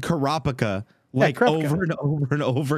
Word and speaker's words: Kurapika: When you pronounce his name, can you pronounce the P as part of the Kuro? – What do you mean? – So, you know how you're saying Kuro-pika? Is that what you're saying? – Kuro-pika? Yeah. – Yes. – Move the P over to the Kuro. Try that. Try pronouncing it rather than Kurapika. Kurapika. – Because that's what it is. It's Kurapika.